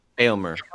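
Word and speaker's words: Éomer: Later that day, do we say Eomer? Is that how you say his name? – Aelmer. – Aelmer. – Yeah, Aelmer.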